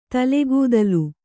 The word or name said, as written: ತಲೆಗೂದಲ್